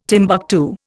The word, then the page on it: Timbuctoo: Indian